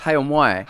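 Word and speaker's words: Hay-on-Wye: Hay on Wye.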